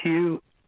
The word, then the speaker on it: Hugh: It's Hugh, H-U-G-H. Last uh-huh. – name, Charlson, is spelled T like Tom. R-A-U-L-S-E-N like Nancy.